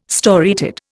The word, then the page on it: storiated: Indian